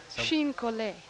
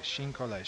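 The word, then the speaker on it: chine-collé: How do you pronounce this? C-H-I-N-E-C-O-L-L-E with an apostrophe on it. Something like Chini Cole or something, or you always can do these things better than me. You pronounce English better than me, come to think of it. – Chine Collet. – Thank you. Chine Collet. Chine Collet.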